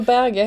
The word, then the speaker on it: Berge: norskt och det uttalas Berge. Det är bara att, att han av någon anledning försöker få någon slags internationell touch på det.